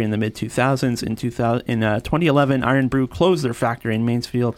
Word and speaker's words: Irn-Bru: So you spell it IRN-BRU, pronounced Iron Brew.